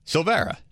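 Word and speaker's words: Silveria: It looks like Silvera. – Dave for Korn. – But yeah. – It's just a silent I.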